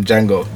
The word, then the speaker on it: Django: It's basically Jamie Foxx said it never happened, and then you know them Django, the D is silent.